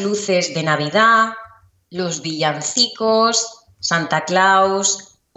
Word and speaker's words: Santa Claus: Es muy similar a la pronunciación del inglés, sí, cuando escuchamos a Santa Claus, pero eh, con un sonido de O, oh, Santa Claus, Santa Claus.